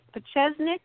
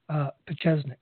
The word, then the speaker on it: Pieczenik: Are you pronouncing it Pachesnik? – Yeah. – Yeah. Okay. – Let me also I'll spell it for you, folks. – Okay. – His last name is spelled P-I-E-C-Z-E-N-I-K. Pachesnik, and that's right. – That's how it was pronounced.